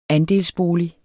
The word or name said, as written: andelsbolig